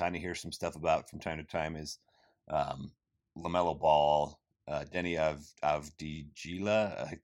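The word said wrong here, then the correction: Deni Avdija: I'm butchering his name. I should have pulled that up before I attempted it. Denny Avdija. There we go.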